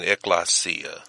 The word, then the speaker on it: ekklesia: Would you pronounce that for them again, Pastor? – Ekklesia. – And it is spelled E-K-K-L-E-S-I-A. E-K-K-L-E-S-I-A. Again, E-K-K-L-E-S-I-A.